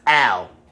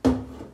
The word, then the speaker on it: Al: Say it with your chest. All right? Say it like you having a good time. Al. That's how I want you to say my motherfucking name every time you say it, lady.